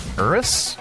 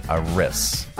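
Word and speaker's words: Eris: How do you pronounce that one? Aris. Okay, I heard you. Aris.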